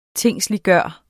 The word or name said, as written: tingsliggjort